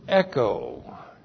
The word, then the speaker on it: echo: We start out with the verb echo. E-C-H long O. Echo.